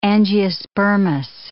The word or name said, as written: angiospermous